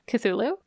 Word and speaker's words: Cthulhu: Is that how you pronounce that? – Cthulhu? – Yeah. – Yes.